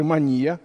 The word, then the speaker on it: мания: Вот есть настоящая мания, или, как говорят психиатры, мания. У психиатров свои ударения.